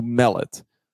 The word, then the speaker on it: Mellet: Brooke Turtle Ghostly says it's pronounced like melon. So Mellet.